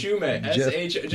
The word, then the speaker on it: Shumay: Jeff, Shume. Just write Shu, and then the month of May.